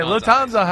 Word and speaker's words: Lufthansa: It was the Lufthansa heist. I think I'm saying that right. Okay, Lufthansa heist. That's right.